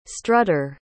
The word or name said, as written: strutter